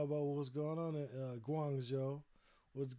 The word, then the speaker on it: Guangzhou: Yeah, Guangzhou. Okay, that's how you pronounce that.